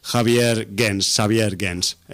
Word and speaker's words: Xavier Gens: que se llama Xavier Gens, si lo pronunciamos así un poco más en francés.